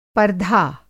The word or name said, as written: पर्धा